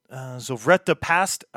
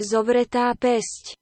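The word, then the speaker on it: Zovretá päsť: They released an album which is called Zovreta Past, and I'm sure that's wrong. Translates into Clenched Fist. That's awesome. Pronounced in Slovak from Google Translate. – Zovreta Pest. – Yeah, it wasn't as far as I thought, but it's still not as close as I'd like to be.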